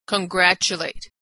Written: congratulate

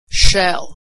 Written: shell

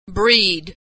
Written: breed

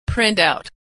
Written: printout

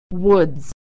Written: woods

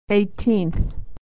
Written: eighteenth

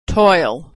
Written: toil

